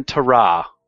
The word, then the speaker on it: Tarah: Let me get her name right. No. Impressed Captain Tarah. T-A-R-A-H.